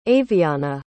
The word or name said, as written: avianna